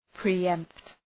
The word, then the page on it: pre-empt: pri:’empt